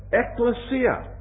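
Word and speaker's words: ekklesia (ἐκκλησία): But Harold Greenlee tells me that the word church is a Greek word that is pronounced something like this, ekklesia, from which we get our word ecclesiastical and ecclesiasticism.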